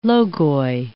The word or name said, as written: Logoi